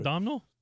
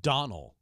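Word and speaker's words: Domhnall: It's Donnell. You don't pronounce the M. You don't pronounce the H. – Hmm. – You finally get to the D, the O. You skip the M and the H. You get to the N, A-L-L. Donnell Gleason.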